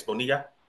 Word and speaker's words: Bonilla: But I got to ask you, and I hope I'm saying this right, Miss Bonilla. – Bonilla, correct.